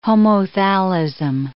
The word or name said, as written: homothallism